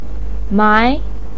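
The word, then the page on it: mai: tone 2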